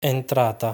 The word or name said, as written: Entrata